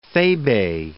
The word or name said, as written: thebe